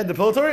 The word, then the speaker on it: depilatory: Yeah, depilatory? That's how you pronounce it? Oh, very good. But that's a word. – How do I say you pronounce it? I need to get an English education? – Depilatory, yeah. – Depilatory? – Depilatory. – Okay, very good.